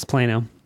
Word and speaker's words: Plano: Plano. – It's there's no noise. – How about you guys shut the fuck up when we finish? There's one in Plano, Texas. There's one in Mohegan. – It's Plano.